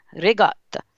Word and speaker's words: ricotta: Its main ingredient is ricotta cheese, or as many Americans say it, ricotta cheese. But we say ricotta.